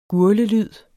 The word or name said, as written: gurglelyd